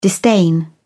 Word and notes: disdain: British English

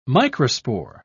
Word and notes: microspore: British English

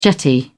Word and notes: jetty: British English

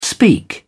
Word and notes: speak up: British English

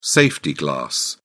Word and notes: safety glass: British English

